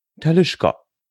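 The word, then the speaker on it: Talisker: Also nein, also schon, natürlich, also ganz seriös, Whisky-Distillerien korrekt ausgesprochen. Talischka. Talischka ist eine Brennerei auf der Isle of Skyr. – Genau.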